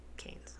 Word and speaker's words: Cairns: It's spelled like C-A-I-R-N. It's pronounced Cairns.